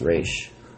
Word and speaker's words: Ra's: It's pronounced Ra's.